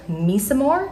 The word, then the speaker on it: Mecimore: So today we are going to be speaking with Ted Mecimore.